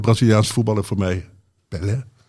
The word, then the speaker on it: Pelé: Maar het is dus officieel Pelé.